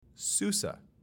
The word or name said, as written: SuSE